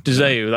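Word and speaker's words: De Zeeuw: Arjan Dazau, I think you pronounce it. – Dazau, that's it, sorry.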